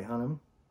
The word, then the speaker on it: Hunnam: Um, in the movie, we got Matthew McConaughey, Charlie Hunnam.